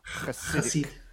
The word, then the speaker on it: Hasidic: I always hear it spelled like sounding Hasidic, and then when I looked at the spelling of it, especially in a lot of like Jewish publications, it's sort of spelled Chesedich, which I'm sure has a lot to do with Yiddish pronunciation. – Oh, the ch, it's like the ch thing, right? – Yeah, Hasidic. Chutzpah.